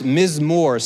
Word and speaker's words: mizmor: In Hebrew, that is pronounced mizmor. Say mizmor.